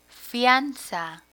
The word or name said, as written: fianza